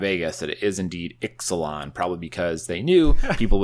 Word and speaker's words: Ixalan: It is pronounced Ixalan. When it was spoiled, everyone was like, oh, it's Ixalan. And then a couple people are like, no, it's Ixalan, because the X is pronounced as a CH in the Mayan dialect.